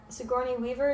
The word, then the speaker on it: Sigourney Weaver: Another thing I know about this is that Sigourney Weaver, is that how you pronounce her name? – Yes.